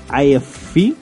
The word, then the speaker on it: AFI: Así es, y bueno, nos vamos a ir con otro pequeño bloque musical y vamos a poner algo de AFI e, que es de AFI, bueno, así lo pronunciamos en español.